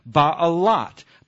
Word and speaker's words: Ba'alat: It's b and that's a guttural uh, vowel that you don't pronounce. That's a l and that's a t. Ba You know, ba'alat. Ba'alat is the feminine version of Baal.